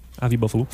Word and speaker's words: Avi Buffalo: C'est bon, ça, Avi Buffalo? – Oui. – C'est bien ça, la prononciation? – Oui, c'est ça. – Avi Buffalo.